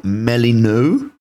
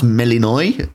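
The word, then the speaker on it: Melinoë: you will be playing a female character called Melino. It's pronounced Melinoy, but it's written Melino.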